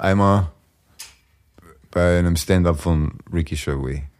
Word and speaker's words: Gervais: Ricky Gervais. – Gervais, nicht ja. Wie Gervais Obstgarten. – Ja, Gervais. Ricky Gervais.